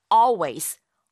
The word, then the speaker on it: always: 실제 발음할 적에는요. all 하는 그 all, 리을 발음을 아예 하지 않아요. 그래서 always, always라고 발음하게 됩니다.